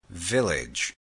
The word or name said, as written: village